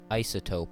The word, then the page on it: isotope: American English